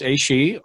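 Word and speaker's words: Aos Sí: So Aishi, pronounced Aishi,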